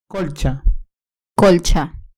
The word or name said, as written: colcha